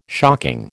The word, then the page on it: shocking: American English